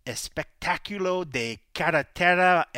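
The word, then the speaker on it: Espectáculo de Carretera: I feel like I'm gonna butcher this because I looked up online, but I feel like we should actually today call the episode the Espectaculo de Carretera MMA. – Oh, nice. You didn't butcher that at all, bro.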